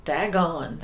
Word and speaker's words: Dagon: D-A-G-O-N. – Dagon. That's how I would pronounce that. Dagon.